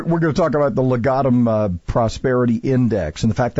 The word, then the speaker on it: Legatum: Hang on, I got somebody here that wants to give you, make sure we got the proper pronunciation. – The 2019 Legatum Prosperity. – So you got it right, yeah.